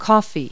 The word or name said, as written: coffee